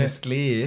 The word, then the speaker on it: Nestlé: verschiedene, Nestlé.